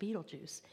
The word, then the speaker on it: Betelgeuse: And it really is pronounced Betelgeuse.